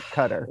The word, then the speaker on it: Qatar: Everybody was very careful to pronounce it Qatar.